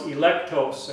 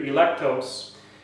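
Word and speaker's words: electos (ἐκλεκτός): The Greek word, by the way, for elect is pronounced electos. I kind of like that in Greek, electos.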